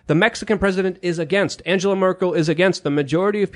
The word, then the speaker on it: Angela Merkel: Angela Merkel. What Mexican president? – That's how you pronounce her name.